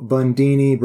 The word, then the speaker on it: Bundini: Drew Bundini Brown. He went by Bundini. – Yeah. – Rhymes with Houdini.